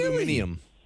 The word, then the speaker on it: aluminium: I went to school for chemistry, and that is how it's written in the periodic table, that is how it is pronounced in school. – Really? Aluminium.